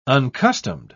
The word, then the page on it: uncustomed: British English